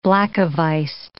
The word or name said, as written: black-a-vised